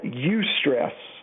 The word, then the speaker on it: eustress: Psychologists and health professionals sometimes call it eustress. And that's spelled E-U, stress. It's good stress, E-U, like where we get our word eulogy at a funeral.